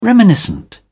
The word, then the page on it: reminiscent: British English